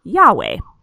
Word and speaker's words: Yahweh: we have written here it's YHWH, but that's pronounced Yahweh.